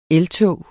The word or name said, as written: eltog